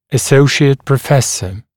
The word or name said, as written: associate professor